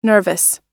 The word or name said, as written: nervous